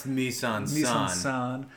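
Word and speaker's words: mise en scène: I think it's pronounced mise en scene.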